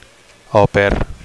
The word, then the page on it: au pair: Catalan